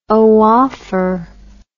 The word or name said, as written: oophore